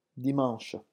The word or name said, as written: dimanche